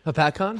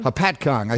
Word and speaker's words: Hopatcong: If you had to bet that palatial estate on Lake Hopatcong, how do you pronounce that word? – Hopatcong? – Hopatcong.